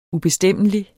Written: ubestemmelig

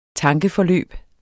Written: tankeforløb

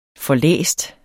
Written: forlæst

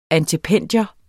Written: antependium

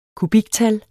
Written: kubiktal

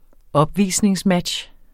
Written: opvisningsmatch

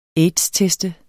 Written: aids-test

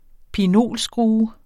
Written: pinolskrue

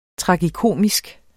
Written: tragikomisk

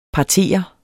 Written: PARTERES

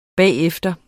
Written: bagefter

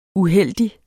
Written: uheldig

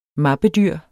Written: mappedyr

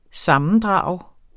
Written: sammendrag

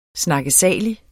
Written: snakkesalige

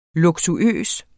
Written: luksuøs

